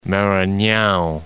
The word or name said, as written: Maranhao